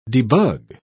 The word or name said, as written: debug